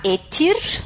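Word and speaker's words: ætt: Se escreve A-E-T-T no singular e o plural etir com IR no fim.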